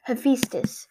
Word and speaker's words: Hephaestus: I think it's either Hephaestus or Hephaestus or, Hephaestus. – Yeah, so it's Hephaestus. I always said Hephaestus for some reason.